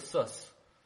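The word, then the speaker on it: Sus (סוס): There's no such thing as a sus. I don't know what a sus is, but sus, as in, you know, you pronounce it like the medical professional who wrote many nursery rhymes.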